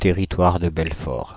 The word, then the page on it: Territoire de Belfort: France (Île-de-France); [tɛ.ʁi.twaʁ də bɛl.fɔʁ]